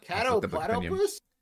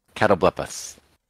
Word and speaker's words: Catoblepus: Catoplatopus? – Shut up. You don't know. – I'm going to call your stupid Australian pronunciation doesn't make any sense to me. – Catoblepus.